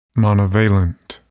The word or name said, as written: monovalent